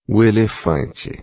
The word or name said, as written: elefante